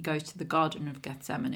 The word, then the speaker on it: Gethsemane: People pronounce it different ways, but the way they pronounce it in the musical is Gethsemane.